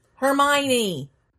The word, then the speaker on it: Hermione: See Hermione spelled out right, it does not make any sense. I'm sorry. That name, the way it's spelled, makes absolutely no sense, and it trips me up.